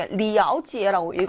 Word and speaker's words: 了解: 그리고 여기 리아오지에라고 읽어야 된다는 것도 중요하죠.